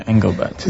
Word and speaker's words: Engelbart: Looking at the background of it, Engelbart, if I got the name right. – Yeah, Engelbart.